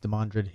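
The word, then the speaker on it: Demandred: He said it was Demondred. Yeah, that's what he thinks.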